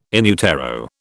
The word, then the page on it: in utero: British English